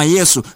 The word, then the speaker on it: Yesu: Kwa lugha ya Kigiriki hili jina linabadilika na kuwa Yeshua, ambalo baadaye linatakiwa kuwa Yesu, yani kwa Kiswahili linatamkwa kama Yesu, lakini Yesu ambaye ni mwokozi.